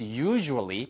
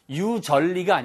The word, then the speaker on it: usually: It's usually 발음 주의하세요. 유절리가 아니고요. Usually 이렇게 들립니다. 아시겠죠?